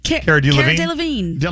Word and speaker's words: Cara Delevingne: Cara Delevingne.